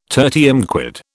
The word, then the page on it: tertium quid: British English